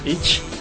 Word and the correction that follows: Inch: No es Itch. – No es Itch. – No es Itch. – ¿Cómo se pronuncia? – Inch. – Inch. – Inch. – Nine Inch Nails.